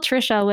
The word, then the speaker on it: Tricia: T-A-N-G-U-Y. And I spell Tricia with C-I-A, not S-H-A.